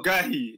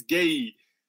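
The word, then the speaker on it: Guéhi: Mark Gerhey told us that it's not Gerhey, it's Gay.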